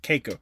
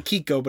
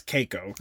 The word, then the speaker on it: Keiko: That's how you pronounce it, Keiko. It looks like Kiko, but it's Keiko.